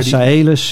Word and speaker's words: Saelus: Ik noem het nog maar even kalius.nl of kalus.nl. – Ja, Saelus.